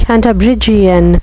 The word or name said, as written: cantabrigian